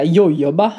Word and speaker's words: jojoba: Uttalar man det så? – Ja, jag brukar säga jojoba.